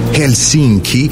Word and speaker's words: Helsinki: Helsinki. Così lo pronunciano loro.